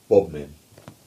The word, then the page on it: Bodmin: English